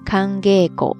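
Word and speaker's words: 寒稽古: 这个时候呢，要读作浊音 kangego ですね。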